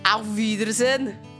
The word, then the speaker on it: Auf Wiedersehen: Auf Wiedersehen!